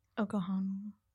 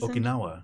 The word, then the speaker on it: Okinawa: Okohana. Like Karate Kid. – I'm lost. – He was from that place. – Okinawa.